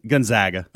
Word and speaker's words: Gonzaga: How do you say? Gonzaga or Gonzaga? – Gonzaga.